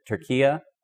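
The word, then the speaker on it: Türkiye: Which, by the way, they're trying to change the pronunciation of the name Turkey. Did you hear about this? Is anything Turkia? Based on the proper spelling of it, because Turkey also has associations with and they want to not have unhelpful associations.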